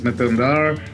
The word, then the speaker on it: Mithun Dhar: My name is Mithun Dhar.